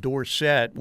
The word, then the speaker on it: Dorsett: hey, it's actually pronounced Dorsett when he got into the NFL.